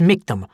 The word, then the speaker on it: Mictum: That's Mictum, rhymes with victim,